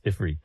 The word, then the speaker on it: Ifrit: But seeing that the etymology is actually like it's spelled Ifrit, you're like, okay, well, it's Ifrit then. – Mm-hmm. – Yeah. – And the jinn, also spelled jinn, D-I-J-I-N-N, or genies, are spirits mentioned in the Quran.